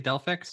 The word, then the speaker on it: Delphix: Delphix? – Yeah.